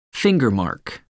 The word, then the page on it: fingermark: American English